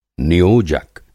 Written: नियोजक

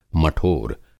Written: मठोर

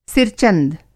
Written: सिर-चंद

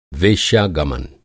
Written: वेश्यागमन

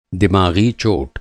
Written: दिमाग़ी

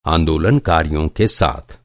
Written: आंदोलनकारी

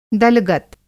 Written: दलगत

